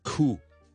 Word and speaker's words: coup: Psychology 另外有個字咧，個 P 咧係放喺最後二，我哋亦都係唔讀 silent P 嚟嘅。 C O U P 正變 cool C O U P。 cool cool cool 就得啦，就唔需要咧 coop 咁嘅。 OK，个 P 唔需要读出嚟。